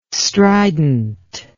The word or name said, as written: strident